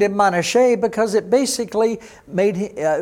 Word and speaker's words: Manasseh: Well, Manasseh, as you would say in English, Be'ivrit in Hebrew is Manasseh. That S is a sh sound.